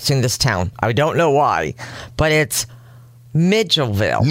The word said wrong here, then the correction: Milledgeville: No, Milledgeville. – Millage, like village, right? – You want to put the D's in first.